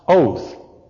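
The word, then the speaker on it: oath (אות): Now, in the Hebrew alphabet, the word for sign is pronounced oath. And it may well be related to our English word, oath, O-A-T-H.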